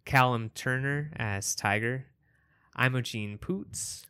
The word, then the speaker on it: Poots: Okay, look, I've got, and I just want everybody to listen to this too, because I looked up how to pronounce it, and this guy, I feel like, is like saying it, he says it three times, and it's almost like, yes, that is her name. Her name is Poots.